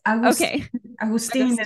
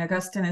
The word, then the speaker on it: Augustin: Yes. Augustin is the pronunciation in Spanish, but it's fine. Augustin is fine too.